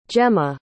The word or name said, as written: Jemma